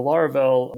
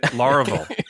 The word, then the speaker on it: Laravel: And I think there's a lot of value outside of that in, you know, the Laravel world that we could kind of bring into Craft and into our plugins as well.